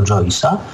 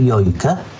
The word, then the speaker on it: Joyce: jak wymawiać nazwisko Joyce'a. No i Sven zaproponował, że może Jojkę. I obaj się potem bardzo zdziwili, gdy im ktoś powiedział, że to należy wymawiać Joyce.